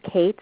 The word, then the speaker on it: Cate: It's spelled I-S-O-B-E-L-L-E, Kate, C-A-T-E.